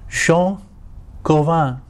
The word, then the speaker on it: Jean Calvin: And actually his name would be pronounced Jean Calvin, because he was French.